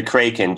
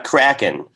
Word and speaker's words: Kraken: The Kraken, JP, not Kraken.